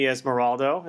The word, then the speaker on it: Esmeraldo: So we're here with Shihan, Manny, Esmeraldo.